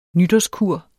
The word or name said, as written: nytårskur